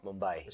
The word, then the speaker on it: Mumbai: Yes, that's correct. The old Bombay, but it's Mumbai now. – Yeah, but you suppose Mumbai is the correct way to say it now.